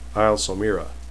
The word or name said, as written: somera